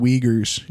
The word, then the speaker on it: Uyghur: They're called Uyghurs, and it's like U-G-Y-R, but it's pronounced Uyghur.